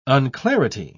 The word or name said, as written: unclarity